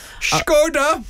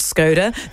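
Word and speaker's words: Škoda: Skoda! – Want us to call it that, not Skoda.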